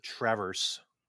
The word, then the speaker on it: Traverse City: I guess I don't know how to pronounce it as a native because I'm not from there. – But okay. – They make halfway decent. It is Traverse.